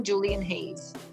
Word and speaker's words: Julian Hayes: I have the pleasure of introducing you to the amazing Julian Hayes.